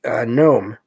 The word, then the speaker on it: gnome: And this one is gnome, which of course the G is silent, G-N-O-M-E.